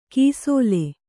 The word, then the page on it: ಕೈಸೋಲು: kai sōlu